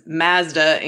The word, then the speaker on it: Mazda: Born and raised in Canada, and the way that we pronounce car names in Canada is slightly different than in the U.S., right? So Mazda, as said in the U.S., is Mazda in Canada.